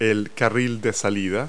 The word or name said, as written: off ramp